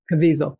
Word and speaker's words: Caviezel: Cavazal. I can't pronounce his name correctly. Anyway, another Caviezel. Rabbit. Caviezel. Thank you.